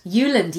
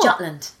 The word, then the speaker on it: Jutland: Is that how you say it? – Jutland, yes, not Jutland.